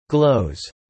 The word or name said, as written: glows